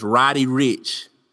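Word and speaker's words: Roddy Ricch: The song of the week this week is called Die Young by this guy named, I want to say his name is Roddy Rucci. Let me get his name right. I don't want to butcher his name. I heard about this guy. Yeah, his name is Roddy Rich.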